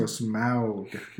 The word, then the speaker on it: Smaug: So Smaug.